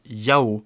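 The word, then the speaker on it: Yao (幺): Ce qui est intéressant, c'est quand les Chinois disent 1, ils disent souvent Yao.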